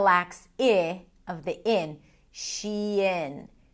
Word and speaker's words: Shein: Shein, which is basically a combination of two words, she and in.